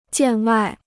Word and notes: 见外: jiàn wài

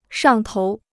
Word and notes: 上头: shàng tou

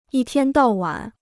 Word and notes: 一天到晚: yī tiān dào wǎn

